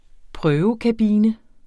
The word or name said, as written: prøvekabine